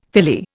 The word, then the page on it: Philly: Indian